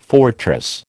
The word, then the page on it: fortress: American English